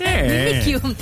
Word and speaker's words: Mimikyu: Mimikyu. – ¿Cómo? – Mimikyu. – Mimikyu. – Yo digo que así se pronuncia. Mimikyu.